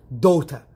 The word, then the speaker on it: daughter: والجي اتش هنا لا ينطق كما تلاحظون جي اتش في وسط الكلمة معروف أنه لا ينطق الذي يستغرب من نطق الكلمة دوتا